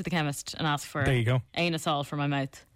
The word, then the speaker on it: anisole: He says it's Anisole. That's how it's pronounced.